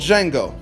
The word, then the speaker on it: Django: It's called Django. The D is silent.